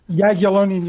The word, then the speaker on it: Jagiellonian: Jagiellonian University.